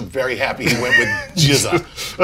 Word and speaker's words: GZA: My guest this week is GZA.